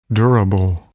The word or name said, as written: durable